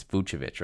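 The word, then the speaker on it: Vučević: Vucevic. There, that's that time I said it right. It's Vucevic right there.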